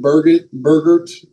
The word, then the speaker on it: Bergert: Both Falter and Bergert is the proper way to say it, pitched on Tuesday, as did Seth Lugo.